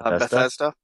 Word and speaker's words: Bethesda: Bethesda? – Thank you.